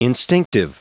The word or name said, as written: instinctive